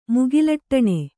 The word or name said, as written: ಮುಗಿಲಟ್ಟಣೆ